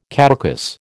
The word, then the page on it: caducous: American English